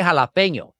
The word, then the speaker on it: jalapeño: Try jalapeño. The J is pronounced like a ja. Then you have the little tilde, of course, on top, the little squiggly thing in the N, which turns into an ñe.